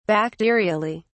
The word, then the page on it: bacterially: British English